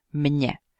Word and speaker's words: mně: Mně. Jak slyšíte, není opravdu rozdíl ve výslovnosti mezi tou formou v genitivu, akuzativu, dativu a jak za chvíli uvidíme, lokálu. Všechno se to vyslovuje stejně. A